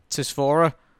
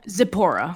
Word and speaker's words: Zipporah: Tisphora? – Zipporah.